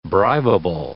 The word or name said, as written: bribable